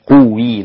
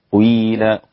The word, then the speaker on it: قيل: ينطقون هكذا: وإذا قيل، وإذا قيل، وإذا قيل. تمام، مش قويل، لا، قيل قيل هكذا.